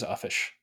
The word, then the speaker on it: uffish: Is it Uffish? – It is Uffish. – As in Uffish thought he stood, the Jabberwock with eyes of flame came whiffling through the tulgy wood and chortled as it came.